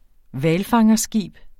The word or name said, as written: hvalfangerskib